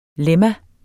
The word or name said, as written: LEMMAET